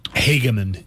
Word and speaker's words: Hageman: Is it Hageman or Hageman? – Hageman is how I've heard it pronounced, yeah.